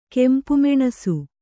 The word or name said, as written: ಕೆಂಪುಮೆಣಸು